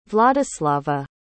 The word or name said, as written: vladyslava